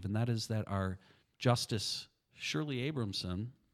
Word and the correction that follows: Abrahamson: is Abrahamson. – Abrahamson. – I got to get that pronounced right.